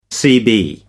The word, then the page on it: cb: American English